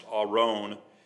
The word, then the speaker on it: Aron: It's pronounced Aron, and is a chest, ark, or Ark of the Covenant.